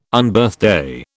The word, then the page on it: Unbirthday: British English